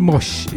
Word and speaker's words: Moshe: So after that Rain in July Rob Redford tune, we had the brilliant Joshua, I think it's pronounced Mosh, it's spelled M-O-S. H-E, his surname,